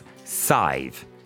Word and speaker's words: Sive: It's Sive. And that is spelled S-I-V-E, like the John B. Keane play. Although her parents did give her the full S-A-D-H-B-H version.